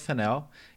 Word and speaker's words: Fennell: Porque o primeiro prêmio foi pra Emerald Fennell. E agora a gente descobriu que a pronúncia do nome dela é Fennell.